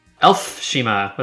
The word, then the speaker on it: Elfshima: Did I say Elshima right? – Elfshima with an F.